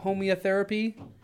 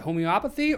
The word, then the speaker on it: homeopathy: Do you have an example of homeotherapy? – Homeopathy.